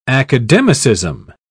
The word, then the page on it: academicism: British English